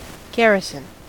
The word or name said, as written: garrison